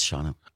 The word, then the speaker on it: Shawano: It's Shawano.